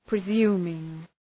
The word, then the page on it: presuming: prı’zju:mıŋ